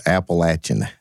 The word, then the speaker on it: Appalachian: A Southern way. It's Appalachian. – Yeah, Appalachian. Like going to Appalachia.